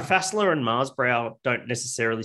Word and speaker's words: Fässla: There's my favorite one is Fässla, F-A, you know, that S that looks like a B. – Yeah. – It's spelled like that, Fässla.